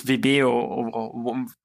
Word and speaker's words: Vimeo: Is there this Vimeo... How do you say it in English? Vimeo? Vimeo? – Oh, Vimeo. – Vimeo, yeah.